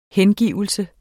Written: hengivelse

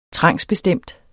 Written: trangsbestemt

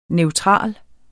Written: neutral

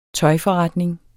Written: tøjforretning

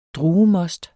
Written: druemost

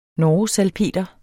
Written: norgesalpeter

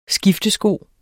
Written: skiftesko

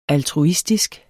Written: altruistisk

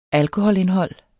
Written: alkoholindhold

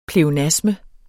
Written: pleonasme

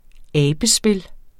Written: abespil

